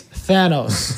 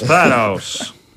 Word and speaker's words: Thanos: It's Thanos. – It is Thanos.